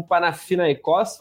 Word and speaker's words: Panathinaikos: com o Panathinaikos, falei certo? Sim.